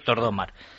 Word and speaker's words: Tordomar: La había pronunciado yo mal con el acento en la última. Y es Tordomar.